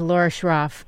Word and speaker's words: Schroff: For those just tuning in, we're speaking with Laura Schroff. Did I pronounce that correctly? – Yes, you did.